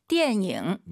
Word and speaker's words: 电影: 电影. – 네, 니은 발음 들리면 안 돼요. 이응입니다. 띠엔잉 꼭 기억하시고요.